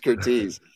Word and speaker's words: Curtiz: I'm gonna help everybody right now and double check the pronunciation, because you know, if people are going to be watching this, then we should at least know what we're sounding like. Michael Curtiz. – It's Curtiz. – It is.